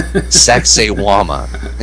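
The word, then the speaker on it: Sacsayhuamán: I know it sounds like sexy woman, but it's not. It's Sacsayhuaman. Yeah, that's the way it's pronounced. It's in Peru. Sacsayhuaman.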